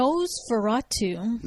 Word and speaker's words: NOS4A2: So this show right here is pronounced. It's NOS4A2. Is that correct? – Nosferatu.